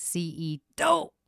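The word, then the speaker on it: C.E. D'oh: During the episode C.E. Dope. – Well, you didn't say that right. It's C.E. Dope.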